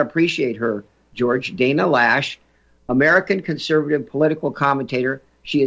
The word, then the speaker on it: Loesch: And I've been mispronouncing her name. Shame on me. Let me mute that video. And Dana Lash, folks. Dana Lash, spelled L-O-E. Let's see here. Let me mute this one. Spelled L-O-E-S-C-H.